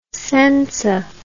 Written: senza

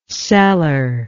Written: cellarless

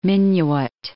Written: Minuit

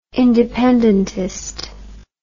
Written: independentist

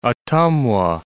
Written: Ottumwa

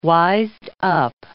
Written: wised-up

